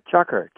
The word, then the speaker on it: chukar: It's a type of partridge, shukar or something like that. Is that how you pronounce it? – Chukar. – Oh, it is.